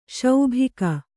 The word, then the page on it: ಸಿಬಿಕೆ: kannada